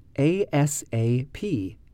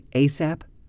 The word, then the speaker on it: ASAP: The first one is ASAP, A-S-A-P. Have you ever heard anyone say ASAP? Now, sometimes we do just use it as a string of letters, A-S-A-P, and sometimes people pronounce it like a word, ASAP.